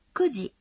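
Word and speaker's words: кюджи (九時): Нет. Когда после цифры «девять» стоит «ди», нужно произносить «кюджи».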